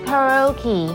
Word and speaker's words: karaoke: 不过注意哦，卡拉 OK 它的英文发音是截然不同的哦。我们要这么说，karaoke，karaoke。 Karaoke, karaoke。